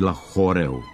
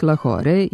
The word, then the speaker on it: Lahore: Oblik Lahore i promjena u Lahoreu nisu pravilni, jer krajnje E u izvornom pisanju tog grada pripada engleskoj transkripciji, ne čuje se u izgovoru i ne prenosi se.